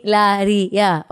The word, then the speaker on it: Hilaria: Hilaria.